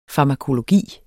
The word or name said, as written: farmakologi